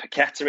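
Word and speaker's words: Paquetá: Amazingly, though, it's the even players that they've inquired about, like Timo Werner, Lucas, I think it's Paqueta it's pronounced, isn't it? – Yeah.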